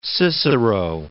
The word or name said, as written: Cicero